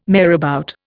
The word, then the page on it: marabout: Indian